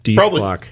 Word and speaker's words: Dethklok: Then there's Death Clock. That's one of his other albums. It's spelled DETHKLOK. Death Clock.